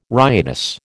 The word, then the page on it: riotous: American English